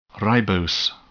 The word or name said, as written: ribose